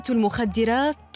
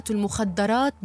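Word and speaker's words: المخدِّرات: طالب شاطر تطلق كلمة شاطر ويراد بها الذكي الألمعي وذلك خطأ فالصحيح أن يقال طالب ذكي وليس طالب شاطر كلمة المخدرات تلفظ بكسر حرف الدال مع تجديده فهي اسم فاعل من الفعل الرباعي خدر وقول البعض كلمة المخدرات بفتح الدال خطأ شائع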